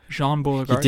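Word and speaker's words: Baudrillard: Jean Beauregard. I think I said it right that time. Jean Beauregard. – You did it, finally.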